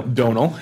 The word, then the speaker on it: Domhnall: Domhnall Gleeson. – Donal Gleeson. – Is that how you say it? – I Googled it before the last time I had to say it. – There you go. – Donal, yeah.